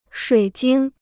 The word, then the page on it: 水晶: shuǐ jīng